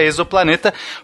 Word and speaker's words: exoplaneta: E, e assim, pelo português correto, seria exoplaneta, porque a gente fala exógeno, a gente fala exoesqueleto, a gente fala exoderme, exoplaneta.